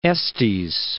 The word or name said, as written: Estes Park